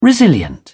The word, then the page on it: resilient: British English